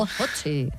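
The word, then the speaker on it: Ötzi: Otzi creo que se llamaba, un nombre muy, muy noruego. – Utzi, ¿no? – Pues sí, Utzi. – No sé, lo pronuncias tú mejor que yo. – Utzi, vale.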